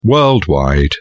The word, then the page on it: worldwide: British English